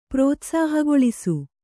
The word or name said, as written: ಪ್ರೋತ್ಸಾಹಗೊಳಿಸು